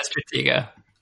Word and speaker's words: Stratego: So Risk, I remember my dad always bought the games like Risk and Stratego? Is that how you say it? – Yeah, Stratego.